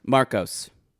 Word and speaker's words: Marcos: Below Deck Land Ho, and it was like following Marcus in a restaurant... – Marcos.